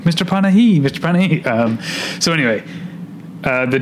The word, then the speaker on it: Panahi: I saw Three Faces where they say his name a million times, so I have to remember it's Panahi. – Panahi. – I've always said Jafar Panahi, it's Panahi.